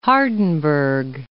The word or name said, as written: Hardenberg